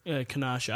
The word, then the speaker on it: Kenosha: So to back it up, yeah, the reason why the Bucks were boycotting was over the shooting, and it was like, does anyone know the pronunciation of this? Kenosha? – Yeah, Kenosha. I don't know. – Kenosha, Wisconsin.